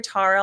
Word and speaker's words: Tara: When I hear Tara, I'll politely say, no, my name is pronounced Tara. Now, there's been a couple of times, one in particular call center gal from Louisiana said, you know, blah, blah, blah, Tara. I'm like, yeah, it's Tara. She goes, are you sure isn't it Tara?